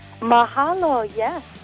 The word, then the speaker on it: Mahalo: Mahalo? – Mahalo, yes.